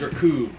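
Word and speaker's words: Recoome: My name is Raccoon, which rhymes with doom.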